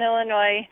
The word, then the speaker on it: Illinois: We live in Illinois. Silent S.